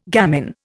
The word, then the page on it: gamin: Indian